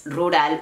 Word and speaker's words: rural: Nuevamente, es difícil detectar si es una sílaba o son dos. Yo diría que es sílaba y media. No me hagan caso, me lo acabo de inventar. Pero a ver, escuchen. Rural. Rural. Rural.